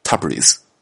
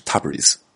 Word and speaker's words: Tabriz: 买从亚美尼亚到伊朗的大巴车票的时候啊，售票处的人告诉我，这个是上午十点半从埃里温发车，次日凌晨四点抵达大不里士，啊，塔布里斯。大不里士是伊朗西北部的一个城市啊，呃，按照英文发音的话是 Tabriz。